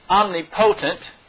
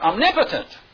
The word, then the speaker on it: omnipotent: It's not omnipotent, which you could pronounce it that way, but it's really pronounced omnipotent.